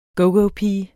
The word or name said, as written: gogopiger